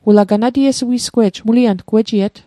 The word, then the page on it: ganatiesui'sgwe'j: Mi'gmaq